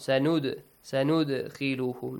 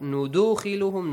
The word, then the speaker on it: سَنُدْخِلُهُمْ: سَنُدْخِلُهُمْ Not نُدُخِلُهُمْ, no. نُدَخِلُهُمْ, no. نُد. Just an echoing sound.